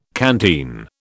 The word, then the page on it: canteen: British English